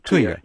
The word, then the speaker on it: Touya: Touya, yes.